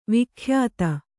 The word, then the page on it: ವಿಖ್ಯಾತಿ: vikhyāti